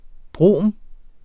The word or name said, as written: brom